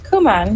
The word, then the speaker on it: Kumin: Kumin comes from it's a Japanese company, and it's actually the last name of the founder. His name was Toru Kumin. – Mm-hmm. – So it's pronounced Kumin.